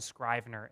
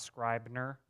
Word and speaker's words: Scrivener: It is Scrivener, not Scribner, which we said last week.